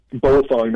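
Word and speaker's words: Baofeng: Some people, Bofunk, that's spelled B-A-O, and then F-E-N-G.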